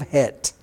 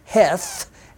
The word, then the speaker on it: Het: And remember, in Hebrew, when you see a TH, you don't pronounce that as Heth, as you would see in your English Bible, H-E-T-H, because in Hebrew you don't have that TH sound. That's just there to accommodate English speakers.